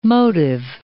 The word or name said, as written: motive